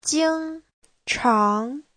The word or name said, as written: jing1chang2